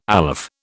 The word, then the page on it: aleph: British English